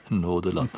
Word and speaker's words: Nodland: nådeland. Det uttales Nådeland, mens det skrives Nodland, men det ble virkelig et nådeland.